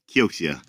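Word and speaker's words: Kioxia: How do you guys pronounce it? – Kyoxia. I usually say like an old Yakuza gangster.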